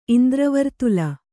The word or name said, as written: ಇಂದ್ರವರ್ತುಲ